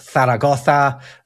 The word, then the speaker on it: Zaragoza: But in Castilian Spanish. Yeah, it is. It's Barcelona. You've got Zaragoza as well. Sevilla, that are pronounced with this th sound.